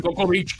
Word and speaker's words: Cocoa Beach: En, Coco Beach, claro.